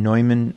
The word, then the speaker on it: Neumann: Yeah, and there's also St. John Neumann, or St. John Neumann, the more German pronunciation, who's from Bohemia, you know, the Czech area, and became the fourth bishop of Philadelphia.